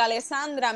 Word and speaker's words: Alessandra: Alessandra mi nombre se escribe con doble S Eso es lo más importante.